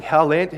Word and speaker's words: Haaland: There is a footballer, Erling Haaland.